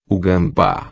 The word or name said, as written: gamba